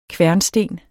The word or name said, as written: kværnsten